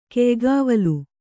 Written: ಕೆಯ್ಗಾವಲಿ

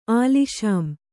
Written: ಆಲಿಷಾಂ

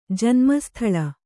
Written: ಜನ್ಮಸ್ಥಳ

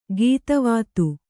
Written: ಗೀತಿವಿದ

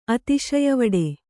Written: ಅತಿಶಯವಡೆ

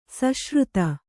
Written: ಸುಶ್ರುತ

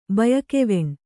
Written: ಬಯಕೆವೆಣ್

